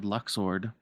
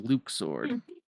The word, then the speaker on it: Luxord: We weren't sure how to pronounce it, so everyone just said Luxord. But in a future game, we hear it said, and it is Luke's sword.